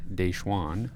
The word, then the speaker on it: DaJuan: Uh, so the new additions since we last spoke are it's spelled like Dewan, but it's pronounced Deshawn Gordon, transfer from Kansas State.